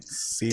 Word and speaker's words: Siwa: I mean, JoJo Suiya, is that how you say her name? – Siwa? – Dance Kids.